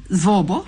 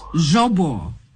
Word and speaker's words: Zvobo: I'm good, and I'm just hoping that I didn't butcher your name and surname, Zvobo. – Yeah, you got it correctly. You pronounce it like a Russian, actually, so that's really good. – How is it supposed to be pronounced? – Zvobo. – Zvobo.